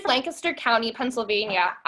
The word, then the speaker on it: Lancaster: And a girl asked me, like, I like told her I was from Lancaster, and by the way, it's pronounced Lancaster.